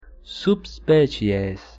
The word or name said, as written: subspecies